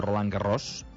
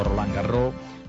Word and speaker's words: Roland Garros: O Roland Garros, si ho féssim en pronúncia a la catalana, allò que es diu a la catalana. O Roland Garros, si ho féssim una mica més a la francesa.